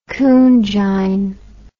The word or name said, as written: coonjine